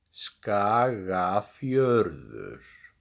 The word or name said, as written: Skagafjörður